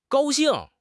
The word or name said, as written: gao1 xing4